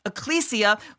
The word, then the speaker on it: ekklesia: That's a language used for the Greek term ekklesia, or pronounced ecclesia, for us being called out ones that people would see that we carry this banner that the Lord is victorious.